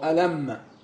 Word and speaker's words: ألم: لكن هي الم تنطق هكذا خفيفه لانها يقصد بها الاستفهام الم نشرح لك صدرك يا محمد ما قالوش الم الم معنى تاني خالص